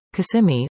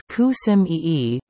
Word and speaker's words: Kissimmee: When we moved to Florida in the early 2000s, we realized that we were pronouncing the name of one of our favorite Florida cities, Kissimmee, all wrong. It was Kissimmee, despite the prominent kiss.